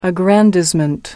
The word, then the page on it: aggrandizement: American English